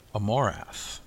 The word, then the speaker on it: Amorath: Amor, which means love, and wrath, which means hate. Amorath, the yin and yang.